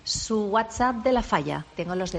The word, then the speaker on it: WhatsApp: Qué bien pronuncia esta oyente. – WhatsApp. – ¿Es WhatsApp. – Sí. Fantástico. – Todas las veces lo ha dicho.